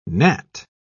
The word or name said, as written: gnat